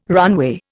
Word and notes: runway: Indian

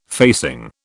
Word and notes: facing: British English

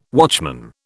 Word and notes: watchman: British English

